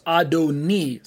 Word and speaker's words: Adoni: Adonai is not pronounced the same way. It suddenly becomes Adoni. Sounds like a different vowel at the very end. It looks the same without the vowels, but when we put in those little dots and dashes, we end up with a transliterated A-D-O-N-A-I, pronounced as Adon plus E.